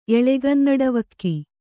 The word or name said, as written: ಎಳೆಗನ್ನಡವಕ್ಕಿ